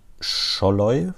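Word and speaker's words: Sallai: Auch bei Palermo gespielt, äh, der ja jetzt mittlerweile bei Freiburg eine, eine sehr, sehr gute Nummer ist, ist äh, Roland Scholloy, wird das wahrscheinlich ausgesprochen, der Ungar. Mhm, genau, ja.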